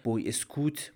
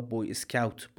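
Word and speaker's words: Boy Scout: مجله بوی اسکوت با تلفظ فرانسوی یا بوی اسکاوت با تلفظ انگلیسی این اتفاق در ماه آپریل 1922 افتاد.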